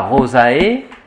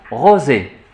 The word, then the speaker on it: rosae: On ne dit pas Rosae, dans la prononciation restituée, on dit Rosé. Diphtongue prononcé comme E.